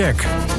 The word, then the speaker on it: Shaq: En Shaq spreek je uit als Shaq. Dus het is Shaq. Je mag ook Shaq of Shaq zeggen. Maar Shaq is denk ik de beste betiteling. Omdat het ook vanaf Shaquille afgeleid is natuurlijk.